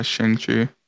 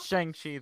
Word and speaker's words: Shang-Chi: I hope you get your ass kicked by Shang-Chi.